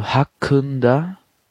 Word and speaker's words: hakkında: Beim hakkında müsst ihr aufpassen. Und zwar, das I ist ohne Punkt geschrieben im Türkischen. Und das bedeutet, dass es, wie soll ich sagen, sehr stumpf so ausgesprochen wird. Also mehr so ein Ö statt ein I. Und also hakkında. So wird es ausgesprochen.